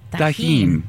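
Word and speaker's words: Tajín: Tajin, but it's pronounced tahine.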